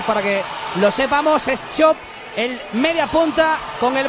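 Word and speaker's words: Čop: Cop se pronuncia Chop. – Chop. – Chop, vale, chop. Vale. Como la sardilla, chip y chop.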